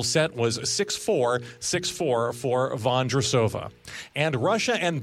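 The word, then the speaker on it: Vondroušová: Vondrosova.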